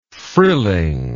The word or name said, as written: frilling